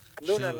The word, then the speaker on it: Luna: So, how do you say her last name? – Leah Luna. – Leah Luna, like Luna, the moon.